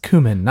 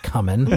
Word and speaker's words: cumin: It's pronounced cumin, not cumin.